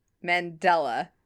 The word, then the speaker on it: Mandela: Mandela.